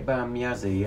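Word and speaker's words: Ege Bamyasi: Is it Egbamyazi? Egbamyazi. – Egbamyazi, yes.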